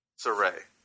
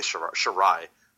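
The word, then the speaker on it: Sarray: Because, I mean, it was, I don't know much about, how do you say her name again? – Saray. – Saray. For some reason, I was going to say Shirai, but whatever.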